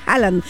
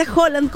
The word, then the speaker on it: Haaland: Y cómo no hablar del fenómeno futbolístico del momento, de nuestro gran Haaland, porque no se dice Haaland, se dice Haaland, como él mismo lo dijo, así que no me canso de decir Haaland.